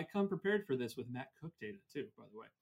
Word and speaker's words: Koch: The pitcher is uh, Matt Matt Cook.